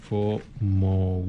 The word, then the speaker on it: FOMO: Se pronuncia así como se lee FOMO.